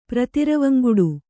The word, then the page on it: ಪ್ರತಿರವಂಗುಡು: kannada